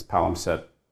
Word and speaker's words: palimpsest: Did I miss an S? – Palimpsest. Yeah. – Palimpsest. There's another S before the.